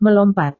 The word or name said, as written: melompat